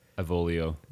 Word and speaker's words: Avolio: Like, right after, like, the main character, like, when I hit main character Aviolio. – Avolio. – Damn it. I knew I said it wrong again.